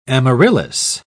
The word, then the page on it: amaryllis: British English